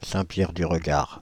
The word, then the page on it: Saint-Pierre-du-Regard: Paris